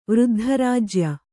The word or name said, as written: ವೃದ್ಧರಾಜ್ಯ